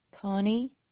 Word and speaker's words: Connie: Connie Hoover.